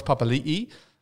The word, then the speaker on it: Papali'i: We had Charles Nickel Klukstar, and we've got Josh Papali'i.